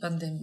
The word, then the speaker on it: пандемия: пандемия, да, ударение на букву «и».